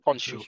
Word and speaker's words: Khonshu: Konsu? Khonshu? – Kon... Khonshu.